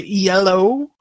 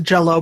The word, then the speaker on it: Yello: This is 8-Bit Box by Yello, if that's how you pronounce it. That I want to pronounce it Jello, but I know that's wrong. – that is indeed not how you pronounce that word.